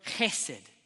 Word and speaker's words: chesed: If you have a little spittle when you say it, you know you're saying it correctly. It's not he. It's not H. It's a ch. Okay? Ready? So, everyone with me, chesed.